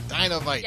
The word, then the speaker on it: Dynavite: Like the Dynavite, is that how you say it? – Dynavite. – Yep.